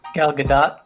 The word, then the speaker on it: Gal Gadot: Gal Gadot? – Is that how you say it? – Yeah. – Man, I've been saying it wrong this whole time.